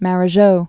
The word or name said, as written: Marajo